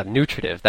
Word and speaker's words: nutritive: Yeah, nutritive. That's the emphasis on the new.